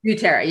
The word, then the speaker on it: doTERRA: And, I've been having this terrible cough, and she had mentioned that, is it pronounced doTERRA? doTERRA? – Yeah. doTERRA. Yeah.